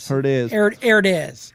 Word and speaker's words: Herdez: Herdez. – I'm sure Herdez.